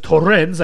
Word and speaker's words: Torrens: Actually, I do believe it's Torrens. I've been putting the accent on the wrong syllable.